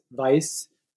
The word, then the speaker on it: Weiss: Today I'm interviewing Dr. Heinz Weiss about his book, Trauma, Guilt, and Reparation, published in 2020 by Routledge. Dr. Weiss, and we pronounce it with a V, right? Weiss?